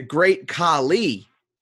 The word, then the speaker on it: Khali: You did not say it right. The Great Khalid is not correct.